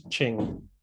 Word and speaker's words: Qing: I have to say that for some reason it is pronounced Qing. – Oh, it's Qing.